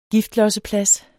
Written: giftlosseplads

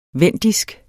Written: vendisk

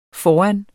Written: foran

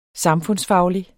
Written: samfundsfaglig

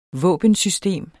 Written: våbensystem

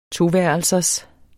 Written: toværelsers

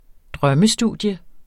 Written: drømmestudium

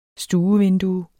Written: stuevindue

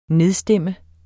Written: nedstemt

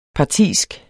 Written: partisk

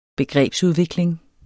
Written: begrebsudvikling

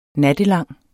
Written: nattelang

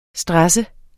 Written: STRESSES

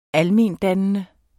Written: almendannende

